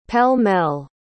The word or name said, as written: pell-mell